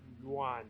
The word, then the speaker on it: yuan: It's yuan. – Yuan? – I think it's pronounced yuan. It's like Y-U-A-N.